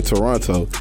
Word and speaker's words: Toronto: It's funny, you the first dude ever heard pronounce the second T in Toronto.